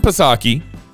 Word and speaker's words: Psaki: Jen Psaki believes teachers should be allowed to talk to kindergartners about being a boy or a girl.